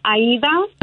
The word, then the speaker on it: Aida: So it's Aida, okay, from Aida Cuevas, the mariachi singer, or Aida from the Italian opera.